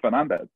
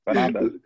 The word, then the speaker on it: Fernandes: I say Gomez, like you were Bruno Fernandes, and the commentators are like Fernandes, and you're like, what? No, it's Fernandes. Fernandes.